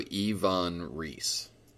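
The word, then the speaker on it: Iwan Ries: And it is pronounced like it's I-V-A-N, so Ivan Rees.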